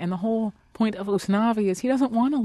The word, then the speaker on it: Usnavi: Usnavi, the name of your protagonist, which is spelled U-S-N-A.